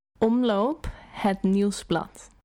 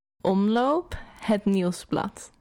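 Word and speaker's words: Omloop Het Nieuwsblad: Omloop et Nieuwsblad. – Okay, it's not bad, but the proper way to pronounce it is Omloop et Nieuwsblad. Omloop et Nieuwsblad.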